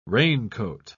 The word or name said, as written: raincoat